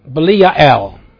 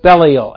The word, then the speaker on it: Belial: Or as the Hebrew text calls him, Yoetz Belial, or Belial, as you may pronounce it in English.